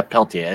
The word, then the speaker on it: Peltier: Yeah, Peltier, isn't it? – Yeah, Peltier, that's right.